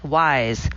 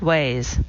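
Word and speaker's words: Weyes: Memo to Chalamet, the first word in her stage name is pronounced like wise, not ways.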